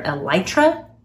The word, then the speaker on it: elytra: Scientists have finally discovered how it has to do with this composition and structure of their elytra. Did I say that correctly? – Yes, I believe so.